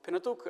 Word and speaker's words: Pentateuch: По-английски «Pentateuch». Может быть, немножко другое произношение. Пожалуйста, запомните это слово. Оно очень часто пригодится вам. Вы будете слышать его «Пентатук» именно со значением «пять книг» или «пять свитков», «пять сосудов», в которых хранили свитки.